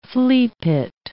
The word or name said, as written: fleapit